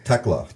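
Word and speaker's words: Thekla: Tekla. – Tekla. – The H is silent.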